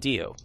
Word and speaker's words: Diox: Named Dio. – Named Dio. Spelled D-I-O-X, the X and the is X is silent.